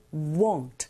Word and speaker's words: won't: 이때 WANT와 WON apostrophe T 이걸 구분하실 때 WON apostrophe T는 워, 워, won't 조금 더 동그랗게 입을 모아서 발음하시면 원하다라는 동사 want와 구분하실 수 있어요.